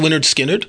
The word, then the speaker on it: Lynyrd Skynyrd: The first Lynyrd Skynyrd album, pronounced Lynyrd Skynyrd.